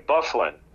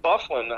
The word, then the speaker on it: Byfuglien: He pronounces his name Byfuglien. And I said, you got to be kidding me. I said, how do you get Byfuglien out of B-Y-F-U-G-L-I-E-N, you know? And he said, I don't know how they do it, but that's the way he wants it pronounced.